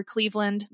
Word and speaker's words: Cleaveland: But you can find me on Instagram, just at Piper Cleveland.